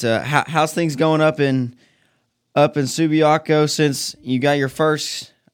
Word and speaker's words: Subiaco: It's Subiaco. You said it right the first time.